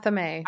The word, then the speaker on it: athame: and anthem, the knife. – Oh, and athame. – Athame, yes. I can never ever remember how to pronounce that.